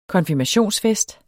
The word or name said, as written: konfirmationsfest